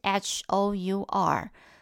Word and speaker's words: hour: 比如说，我举个例子，像 hour 好了，hour 是小时，对不对？an hour，对啊，为什么它 hour 拼音明明是 h o u r，可是呢，它却要用 an hour，是因为那个 h 不发音，所以它发音的时候开头是 ow，它是母音，好，所以这时候就要用 an hour，你就没有办法单纯的用这个。